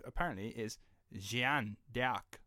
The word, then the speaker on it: Jeanne: Jeanne d'Arc, apparently, is how it's supposed to be pronounced. Is like there's a H in it, like J E H A N N E, Jeanne, right? – But that sounds very French.